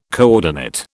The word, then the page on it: coordinate: British English